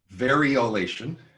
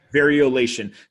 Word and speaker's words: Variolation: That plan B is considered Bitcoin, but in the pandemic world, this plan B, and I may pronounce this incorrectly, but it's Variolation. – Variolation. – Variolation.